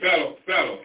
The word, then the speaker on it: fellow: I mean fellow, F-E-L-L-O-W. Is that fellow? Am I saying that right? F-E-L-L-O-W. – Fellow. – A poor fellow. Yeah, I said that wrong.